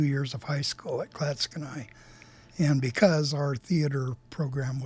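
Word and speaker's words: Clatskanie: But Klatskanai is spelled C-L-A-T, Klatska, S-K-A, Nye, N-I-E, that one's, or knee. How do you pronounce it? – Klatskanai. – Yeah, but that one could go either way.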